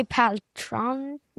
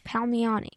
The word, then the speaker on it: Palmiotti: Yeah, it's Amanda Connor and Jimmy Paltrano. – Palmiotti.